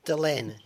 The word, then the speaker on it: Delenn: Is it Ambassador Delenn? – Delenn, yes.